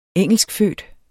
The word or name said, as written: engelskfødt